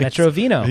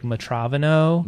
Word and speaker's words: Metro Vino: Even Metro Vino, yeah. – How it was connected. Some people were like Metravino.